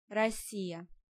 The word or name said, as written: Rossia